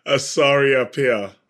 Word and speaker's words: Asare-Appiah: Asare-Appiah.